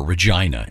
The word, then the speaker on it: Regina: The city that rhymes with fun and show us your Regina. And right. – the very first line, it says... pronounced like vagina.